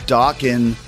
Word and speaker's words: Dokken: And I remember the first album or one of the albums had a sticker on it, and it said Dokken rhymes with Rockin'.